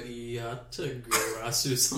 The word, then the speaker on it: Yatagarasu: If I had Amaterasu, I could have thrown 10 of the Yatagarasus onto it.